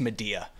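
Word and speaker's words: Madea: but I'm not black enough to know that it's pronounced Medea.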